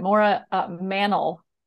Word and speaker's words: Maura Mannle: Did I say your name right, Maura? – Nope. No, it's okay. But that's how everyone else would say it. Maura Mannle. – Mannle.